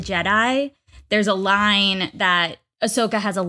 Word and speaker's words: Ahsoka: Wait, is that, am I saying her name right? Ahsoka? – Ahsoka.